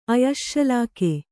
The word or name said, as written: ಆಯಶ್ಶೂಲಿಕ